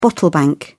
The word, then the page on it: bottle bank: British English